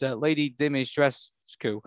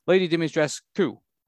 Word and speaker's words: Dimitrescu: Romanian speakers in replies pointed out that the fans were not far off. They would pronounce it Lady Dimitrescu. This is the same to the assumed pronunciation. It's more the emphasis on the "ku" at the end.